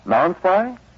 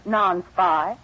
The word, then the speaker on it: Nonspi: Now, may I ask how you pronounce N-O-N-S-P-I? – Well, after hearing how everyone else pronounces non-spy, I pronounce it this.